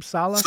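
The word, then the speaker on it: Saleh: Sala.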